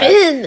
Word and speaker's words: Nahum: While studying at the university, Holmes had apprenticed under Dr. Nahum, N-A-H-U-M, however you wish to pronounce Nahum.